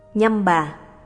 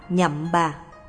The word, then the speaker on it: Nhâm Bà: Nhâm bà, phiên âm từ phạn ngữ là Nim Ba, cũng đọc là Nhậm Bà.